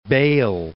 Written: Bayle

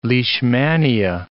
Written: leishmania

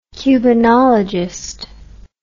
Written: cubanologist

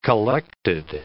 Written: collected

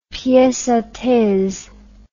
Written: piece a these

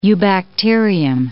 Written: eubacterium

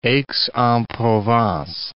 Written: Aix-en-Provence

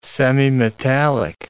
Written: semimetallic

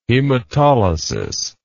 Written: hematolysis

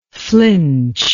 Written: flinchingly

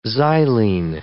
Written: xylene

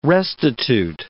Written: restitute

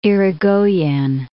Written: Irigoyen

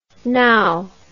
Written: gnow

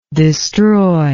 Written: destroyable

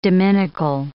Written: dominical

